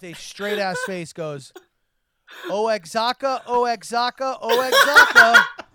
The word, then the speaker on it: Oaxaca: And I go, the white man in the room goes, "I think you meant to say Oaxaca, Oaxaca, Oaxaca, because that would sound like Waka Waka Waka." That's how you pronounce.